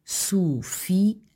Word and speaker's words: Sofia: No caso do nome próprio Sofia, a pronúncia efetua-se com um hiato entre I e A, Sofia, o que nos indica que esta palavra é um trissílabo, ou seja, tem três sílabas.